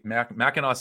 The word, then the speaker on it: Mackinac: By James Homan from the Mackinac Public Policy Mackinac Center for Public Policy. It's spelled Mackinac, but it's pronounced Mackinac. So sorry for anybody in Michigan who had to live through me pronouncing that wrong.